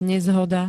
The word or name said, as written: nezhoda